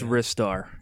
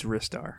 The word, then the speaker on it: Ristar: I actually picked up Restar. I think it's pronounced Restar. Is it Restar or Ristar? You know? – I think it's Ristar. It's Ristar.